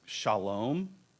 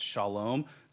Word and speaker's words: shalom: It's shalom. which is badly pronounced, but that's it's shalom.